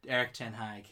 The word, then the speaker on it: Erik ten Hag: Erik ten Hag.